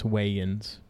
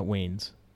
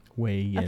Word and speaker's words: Wayans: It's the way the relationship between Bruce Willis's character and Damon Wayans. It just seems so weird and unrealistic. – Wayans. It's Wayans. – Wayans? – Damon Wayans. It's not Wayans. Wayans.